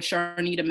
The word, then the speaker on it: Charnita: Okay, so by Instagram, Twitter, and Facebook, you can all of them, you can just search for Sharnita Mack. My first name is spelled C-H-A-R-N-I-T-A.